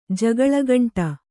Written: ಜಗಳಗಂಟ

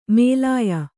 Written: ಮೇಲಾಯ

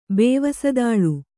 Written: ಬೇವಸದಾಳು

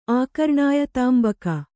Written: ಆಕರ್ಣಾಯತಾಂಬಕ

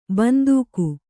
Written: ಬಂದೂಕು

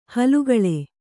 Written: ಹಾಲ್ಗಲ್ಲ

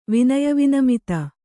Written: ವಿನಯವಿನಮಿತ

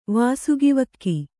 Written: ವಾಸುಗಿವಕ್ಕಿ

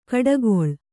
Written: ಕೊಟ್ಟೆಗೋಳಿ

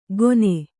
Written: ಗೊನೆ

